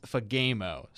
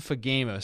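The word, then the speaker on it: Fagemo: And I'll say his last name one more time because people may not know how to say it just looking at it. It looks kind of heinous when you just read his name. But it's Fagamo. Sam Fagamo.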